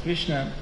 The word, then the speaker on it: Krishna: Krishna Varna, the incarnation of Krishna, which is pronounced in the two syllables, Krishna.